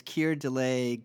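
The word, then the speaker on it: Keir Dullea: I wasn't sure how to pronounce it, but someone said it's Keir Dalea gone tomorrow. – That's a perfect homophonic reminder. Care delay, not tomorrow.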